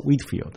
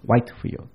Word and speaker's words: Whitefield: 那个人原来是约翰威斯里的同工，就是 George Whitefield，我们叫他怀特菲，在英文他的名字应该读作 Whitefield，不是 Whitefield。